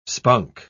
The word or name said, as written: spunk